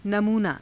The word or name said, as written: namuunaa